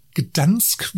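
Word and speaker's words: Gdańsk: Der Flughafen Echo Papa Golf Delta, Gdansk, wie es ausgesprochen wird, ist sehr GA-freundlich.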